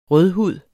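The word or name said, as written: RØDHUD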